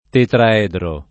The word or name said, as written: tetraedro